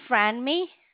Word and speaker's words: Franmi: My name is Franmi.